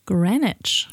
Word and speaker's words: Greenwich: Ich glaube, hier sagt jeder sagt Greenwich-Promenade. Ja, die, die, Schreibweise impliziert es auch. Ne? Sie haben Green, sie haben Witch. Da macht man da einen Greenwich draus. Aber wenn sie es ähm, nach dem britischen Stadtteil aussprechen, wäre es Greenwich.